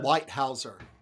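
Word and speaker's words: Leithauser: But Hamilton Lefthauser. – Hamilton Lighthauser.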